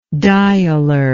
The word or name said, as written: dialer